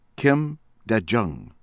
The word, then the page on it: Kim Dae-Jung: American English